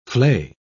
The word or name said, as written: flay